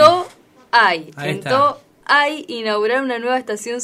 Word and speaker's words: Toay: Toay. Toay, lo dije bien, porque siempre digo Toay y después me cargan. – Arrancaste la U con U.